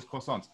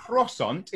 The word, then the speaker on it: croissant: Oh, it's not even, you don't even say the T technically. It's croissant.